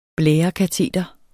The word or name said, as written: blærekateter